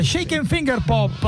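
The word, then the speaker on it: Shake and Fingerpop: Shake and Finger Pop. – L'ho detto bene. – Sì, l'hai detto bene.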